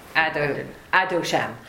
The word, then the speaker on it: Adoshem: So whenever we see that spelling, we pronounce it Adoshem, right? We pronounce it. That's how we pronounce it. We pronounce it Adonai. Right, so it's interesting. In Ashkenazic, we say Adoshem to not say the name.